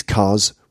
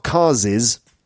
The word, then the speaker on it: cars': So it's C-A-R-S apostrophe. Okay? For example, these cars' windscreens are both smashed. When you're pronouncing it, it's not cars's, you know, these cars's windscreens. No, it's just these cars' windscreens.